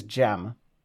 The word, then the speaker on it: Jem: So my nickname is Jem, J-E-M.